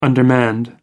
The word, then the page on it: undermanned: American English